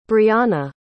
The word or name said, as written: briana